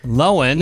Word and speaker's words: Lohan: Lohan. – You know, now that we know how to say her name.